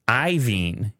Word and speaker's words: Iovine: Iovine. I believe it's Iovine. – Iovine. You're right. It is Iovine. But I never actually looked at it. I just hear people say it. Uh-huh. And now it's spelled Iovine. Skip the O.